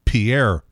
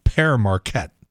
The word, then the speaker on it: Pere Marquette: They say Pierre State Park. It's not. It's Pere Marquette. P-E-R-E. That's not Pierre.